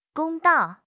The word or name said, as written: 公道